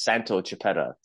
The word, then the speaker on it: Santo Chiappetta: It's so to pronounce my name properly, it's Santo Chiappetta.